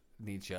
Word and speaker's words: Nietzsche: Nietzsche? – Nietzsche? Is that how you say his name? – Yeah.